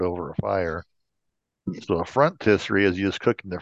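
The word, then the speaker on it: phrontistery: Our first word is frontisserie.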